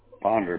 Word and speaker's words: Ponder: Ponder, P-O-N-D-E-R.